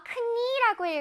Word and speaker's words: knee: K-N-E-E. 이거 뭘까? 무릎입니다. 그래서 우리 친구들 중에 쌤 발음 잘못했어요. 저거 knee라고 읽어요. 라고 한 친구들 혹시 있었나요? 자, knee. 여기 무릎을 할 때 knee는요. K가 여기서 소리가 나지 않습니다. silent예요. 그래서 K 소리가 큰 소리가 나지 않고 그냥 knee라고 읽어요.